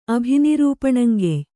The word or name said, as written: ಅಭಿನಿರೂಪಣಂಗೆಯ್